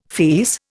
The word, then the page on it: fierce: Indian